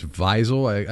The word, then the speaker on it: Wiesel: I think it's Wiesel. I Wiesel? – Yeah. – And it's like we just flipped the W and the V? – I believe so.